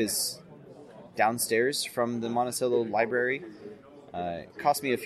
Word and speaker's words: Monticello: I'll be driving from Dallas to Salt Lake, and I'm going to pull over in Monticello, Utah. Yes, it's pronounced Monticello, not Monticello. I don't know if they named it after Thomas Jefferson's house, but that is named Monticello.